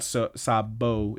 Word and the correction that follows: Sabu: is what I kept saying. It's Sabu. Damn it.